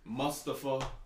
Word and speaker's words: Mustafa: We don't even know how to say his name anymore. It's Mustafa.